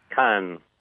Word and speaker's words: Cannes: Cannes.